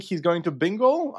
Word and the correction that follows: Bingoal: Bingoal is how it's pronounced.